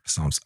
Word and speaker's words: Psalms: Therefore, the silent P in Psalm is a vestige of its Greek origin, reflecting both its historical linguistic journey and the musical roots and ancient religious practices. – Wow. – Psalms.